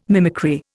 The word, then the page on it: mimicry: Indian